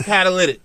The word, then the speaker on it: catalytic: I want to say it's catalytic. It's not Cadillac Converter.